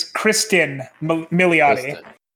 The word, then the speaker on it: Kristen Milioti: It is her name is Kristen Milioti.